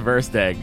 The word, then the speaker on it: Verstig: It's pronounced like first egg, except with a V. So that's Verstig.